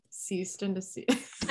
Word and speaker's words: cease and desist: Assist and assist. I'm saying it wrong. Cease and desist.